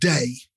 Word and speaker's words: Dai: I've been listening to an artist in Chicago for a couple of years now called Day. It's spelled D-A-I.